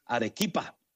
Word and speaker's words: Arequipa: Le mandamos saludos a Rita, que se acaba de conectar ahí desde el Perú, Arequipa, creo que se llama. Perdón, Rita, se lo dije mal. Arequipa, mi hermana, la cafetera Rita, allá en el Perú, ya conectados.